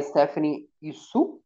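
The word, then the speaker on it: Stephanie Hsu: E ela, de novo, não tem uma boa relação com essa filha, que é interpretada pela Stephanie Isu, Eu acho que é assim que é, se pronuncia. Isso. Uhum. – Isso.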